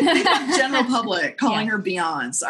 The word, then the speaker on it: Beyonce: Beyonce's always been wonderful, and we met her so early that when she introduced herself, she's introduced herself as Beyonce. It rhymes with fiance.